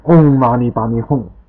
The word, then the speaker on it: Om Mani Padme Hum: 它的发音应该念 Hum，Om Mani Padme Hum。这个老太太说：“ 好的，好的。” 然后她就开始念 Om Mani Padme Hum。